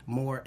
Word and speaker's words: Moore: Moore, MOORE.